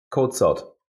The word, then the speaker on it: Kocot: Kocot. C becomes a Z actually, so it's quite complicated.